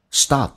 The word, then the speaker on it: stop: Stop，打开口腔，在美式发音有 R 到 R 的一个转变，叫 Stop。 One more time， Stop， P 不发音，这叫略音，但是气流要阻塞一下，因为 P 辅音前面是一个元音，叫 Stop。